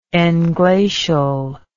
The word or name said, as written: englacially